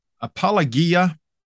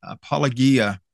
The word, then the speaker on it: apologia: So apologia, you have heard it pronounced other ways. I'm sure I'm using the pronunciation system primarily that I get from Bill Mounts, the system he uses, because that's grammar I usually teach New Testament Greek from.